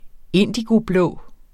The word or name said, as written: indigoblå